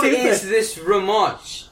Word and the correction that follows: Ramush: Ramush. – Anyways. – Can't even say it right, because I don't...